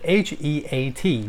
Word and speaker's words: heat: 所以短音 i it it it it it，好，长音 i e。所以呢，h i t 打那个字，你就会念成 hit，hit，hit。但是如果是 h e a t 热那个字，你就会念 heat，heat，heat。所以不一样，hit，heat。